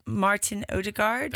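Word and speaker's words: Martin Ødegaard: So Kai Havertz and, is it Martin Odegaard? – That's it.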